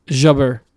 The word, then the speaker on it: Jabeur: Jabeur.